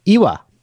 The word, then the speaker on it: Eywa: You try to pronounce it. E-Y-W-A. – Iwa. – Iwa. The quest for Iwa. Iwa. Iwa. – Iwa. – That's great.